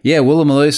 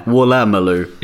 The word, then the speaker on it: Woolloomooloo: The first time, I thought there might have been an extra vowel in there somewhere. But Woolloomooloo, Sydney. – It's probably true. – I told you there's an A in there. Woolloomooloo. – Yeah, you're right. And I was also putting the emphasis on the wrong syllable.